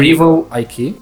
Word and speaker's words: Rival IQ: Então, Rival, como é que pronuncia? – Como é que... Rival IQ. – E como é que é soletra para o pessoal que quiser... – R-I-V-A-L-I-Q.